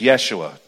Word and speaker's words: Yeshua: It's a horrible mispronunciation. His name was Yeshua, Joshua.